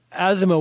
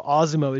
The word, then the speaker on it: ASIMO: Um, Asimo, or Osmo is more appropriately pronounced, has some autonomous functions, but in essence, it's an avatar.